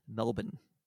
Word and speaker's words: Melbourne: Now, knowing that she's Australian, I believe it's pronounced Melbourne. – I mean, yes, it is.